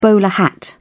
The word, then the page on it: bowler hat: British English